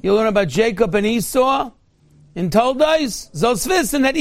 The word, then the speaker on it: Esau: Esau rhymes with hee-haw.